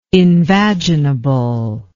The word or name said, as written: invaginable